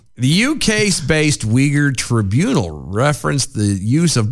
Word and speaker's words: Uyghur: Uyghur, that's right. I keep forgetting we say that wrong every time. – It's Uyghur. – It's Uyghur, uh-huh.